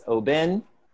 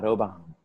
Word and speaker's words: Oban: Yes, and ladies and gentlemen, Nick pronounced it correctly. It is Oban, not Oban.